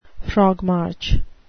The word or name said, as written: frogmarch